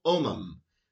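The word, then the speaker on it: Homem: Antonio Omum, I believe that H is silent.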